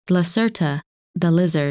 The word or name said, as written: Lacerta